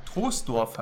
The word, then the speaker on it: Troisdorf: Ja, Troisdorf wird das ausgesprochen, ja? – Genau, genau. – Oh. Okay, wieder was gelernt. Aber das I, das ist ein bisschen fiese. – Das ist das Dehnungs-I, das sitzt aber, glaube ich, nur im Rheinland.